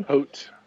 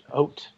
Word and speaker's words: Haut: I mean, still for me with this, how do we say it again? Haut 10? – Haut. – Haut. I want to not say the H. Haut.